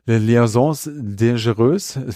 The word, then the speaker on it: Les Liaisons dangereuses: Dan Silver hatte ihn als Schurken schon in der Broadway-Version von Les Liaisons dangereuses. Philipp, habe ich es richtig ausgesprochen? Ja. Danke.